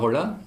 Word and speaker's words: Holler: Und wir heißen als Duo Mona Holler, mit Betonung auf O, weil es wir dann gesagt haben, es ist ja ziemlich ein Holler und nicht Mona Holler, sondern ein Mona Holler.